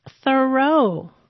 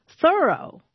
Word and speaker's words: Thoreau: All this time, I thought his name was pronounced Thoreau, but no. – How is it pronounced? – Thoreau.